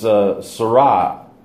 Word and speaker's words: Sarai: And the way that they pronounce it on that site is Sarai. So the A is not really pronounced as much.